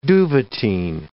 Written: duvetyn

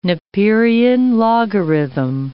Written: Napierian logarithm

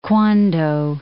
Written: Kwando

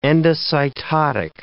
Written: endocytotic

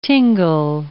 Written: tingle

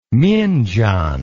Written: myingyan

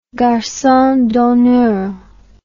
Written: garcon d'honneur

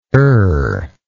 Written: errable

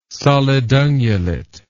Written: solidungulate

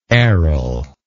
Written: errol

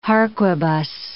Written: harquebus

